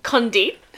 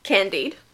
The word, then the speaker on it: Condit: Condit, the guy she was having an affair with. Not Candide.